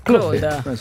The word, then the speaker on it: Claude: Claude.